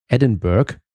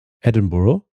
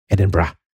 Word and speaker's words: Edinburgh: Auch Edinburgh schaudert mich, denn selbst im Englischen ist das G am Ende stumm. Daher klingt die korrekte englische Aussprache eher wie Edinburgh oder knatschig verkürzt mit gerolltem R bei vielen Schotten Edinburgh.